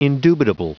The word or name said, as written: indubitable